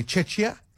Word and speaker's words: Czechia: They play Czechia. That's how you say it.